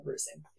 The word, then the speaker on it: Reusing: So my last name is spelled like Reusing. So it's really easy to remember, but it's Kendall and then Reusing.